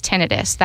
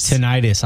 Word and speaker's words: tinnitus: Tinnitus, I called it. Am I saying it wrong? Is it tinnitus or tinnitus? – Okay, so the American Tinnitus Association accepts it either way now.